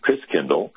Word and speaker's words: Christkindl: And the German pronunciation of Christ child is Christkindl. And over the years, that got pronounced Kris Kringle. So Kris Kringle is really Christkindl, which means Christ child.